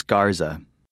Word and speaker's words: Garza: Actually, it's Garza. – How do you spell that? – G-A-R-Z-A.